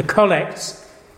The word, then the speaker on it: collects: The collects, and please notice the pronunciation, the collects,